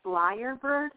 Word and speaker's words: lyrebird: I'm pretty sure it's actually pronounced lyrebird.